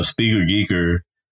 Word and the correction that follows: Speaker Geekers: Speaker Geekers podcast. You gotta pronounce the S. It's plural. Because it's two of them, because.